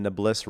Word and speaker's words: Nablus: Nablus Road.